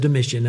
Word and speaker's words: Domitian: I've heard his name pronounced different ways. I follow a couple of different people online, listening to their histories. And there's another Reformed pastor who refers to him as Domitian. And I have never heard Domitian. And so if that is the way you think it should be pronounced, I leave it to you. But I learned it as Domitian, and that's how I will pronounce his name.